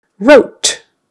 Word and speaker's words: wrote: Wrote. That does have a long O. Wrote. If you say it with a short O, it would be rot, which means something is spoiled.